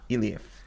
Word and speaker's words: Eilif: Elif? E-I-L-I-F. Elif. – Elif.